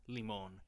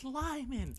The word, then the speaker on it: Limon: And the first time I saw it, I literally pronounced limon, and my friend just goes like, "Where the heck is that?" – Because there's an accent over the O. – And so I'm just like, it just says Limon. And she goes, no, it's Lyman.